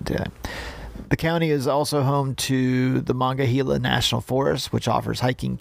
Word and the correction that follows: Monongahela: Oh, did I not say that correctly? Because I know how to say that. I said it incorrectly. I apologize. – I just figured it was a typo by ChatGPT. – Yeah, Monongahela. It's just my North Carolina accent.